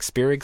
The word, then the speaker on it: Spierig: Am I pronouncing their names right? I think Spierig, Spierig. Sounds good to me.